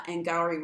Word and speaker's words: Angourie: So Angourie Rice, I think is how you pronounce her name.